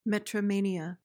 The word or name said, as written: metromania